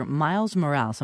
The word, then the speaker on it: Miles Morales: There's a new Superman character, Miles Morales.